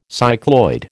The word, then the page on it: cycloid: American English